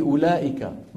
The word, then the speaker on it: أولئك: الواو في أولئك ما أحد ينطقها.